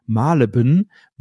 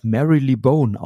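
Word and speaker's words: Marylebone: Also zum Beispiel die U-Bahn-Station Malebin wurde im Hörspiel immer als Mary Lee Bone ausgesprochen, was natürlich voll, vollkommener Humbug ist, aber das wusste man als Kind nicht und deswegen war ich dann irgendwann völlig überrascht, als ich dann in London war und gemerkt habe, das spricht man ja alles ganz anders aus.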